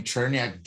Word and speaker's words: Cherniak: Uh, if you uh, know the name Boris, uh, last name Cherniak, difficult to pronounce.